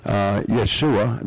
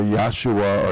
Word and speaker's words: Yeshua: That we do everything just like you would in a synagogue, but we recognize Yeshua. That's the way you would pronounce the Hebrew pronunciation of Jesus. Yeshua or Yahshua or Yeshua. I mean, there are different ways they say it, but it means Jesus.